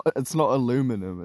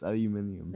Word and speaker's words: aluminium: It's not aluminum, it's aluminium. – It's not aluminum. – It's aluminium. Ugh, I can't pronounce it so bad.